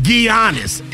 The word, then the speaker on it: Giannis: She said Giannis. His name is Giannis. Oh. – Well, how do we, it's America. How do we know the G is silent? – I know.